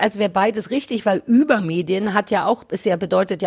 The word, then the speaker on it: Übermedien: Ähm, also, die richtige Aussprache ist eigentlich über Medien. – Mhm. – Ich sage aber reflexartig fast immer über Medien. Einfach, weil es natürlicher klingt, aber es ist, ja.